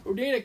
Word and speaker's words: Oneida: He then displayed the Hodag at first in the Odini County? How do you say that? – It looks like Oneida? Oneida? – Oneida County Fair.